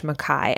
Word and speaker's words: Mackay: And Mackay is M-A-C-K-A-Y. I believe I am pronouncing it right. The reason that I know how to pronounce the word Mackay this way is that the film 1917 that came out a few years ago, which is a great movie, I highly recommend.